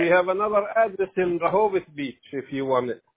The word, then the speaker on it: Rehoboth Beach: Oh, Rehoboth Beach.